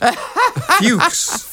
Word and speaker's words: Fux: The third actor listed under Mickey Hargitay and Joseph Cotton is Herbert F-U-X. Herbert Fox. Or Herbert Fuchs. Or Herbert. But how would you pronounce F-U-X? – Fuchs. – Fuchs? – Fuchs.